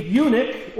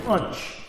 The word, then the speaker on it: eunuch: He's a eunuch, or as one of my confirmation students said once when he was reading the text, an unch. He didn't know how to pronounce that word.